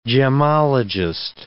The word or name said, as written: gemologist